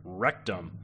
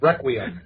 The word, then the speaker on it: Requiem: So tune in next week when we talk Aliens versus Predator Rectum. But until next week, how do you say? It's, it's, it's, it's pronounced, pronounced requiem. Oh, he did it. I just know it as rectum.